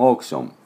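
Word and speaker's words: auction: オークションはですね、 AUCITON ね、これ日本でオークション、オークションとこんな発音ですが、実際はですね、オークション、オークションっていう発音です。でどうかというと、ね、 AU と書いてですね、どうしても日本人の感覚ではこれ、アウって読みたいところなんですが、実際これね、おーなんです。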